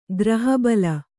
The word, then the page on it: ಗ್ರಹಬಲ: Kannada